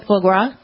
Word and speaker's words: foie gras: Foie, is it foie gras? How do you pronounce that? – Oh, foie gras.